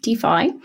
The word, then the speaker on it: Dify: Opera is moving into financial services with the launch of DeFi, that's spelled D-I-F-Y, but it's pronounced DeFi, an in-browser cashback service and digital wallet for online shopping.